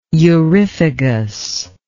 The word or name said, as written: euryphagous